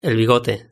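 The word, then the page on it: el bigote: Spanish (Spain)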